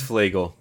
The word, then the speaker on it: Flagel: It's Flagel. It's Flagel. – Flagel. Okay. Flagel rhymes with bagel.